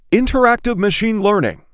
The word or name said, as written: interactive machine learning